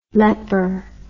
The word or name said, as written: Lepper